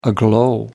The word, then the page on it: aglow: American English